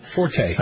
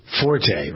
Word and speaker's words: forte: I've always pronounced this wrong. – Forte. – Forte, right.